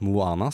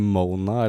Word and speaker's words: Moana: Hvis du ikke veit at det skal uttales Moana, så kan du så si det Mona eller uh, Moaning.